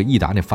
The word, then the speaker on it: 逸达: 今天这期节目我们就说这两款车，哎，没错，长安逸达，对，就是那个逸达那发音啊，但不是那口香糖那俩字儿。